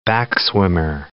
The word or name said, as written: backswimmer